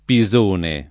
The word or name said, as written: Pisone